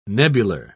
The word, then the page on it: nebular: British English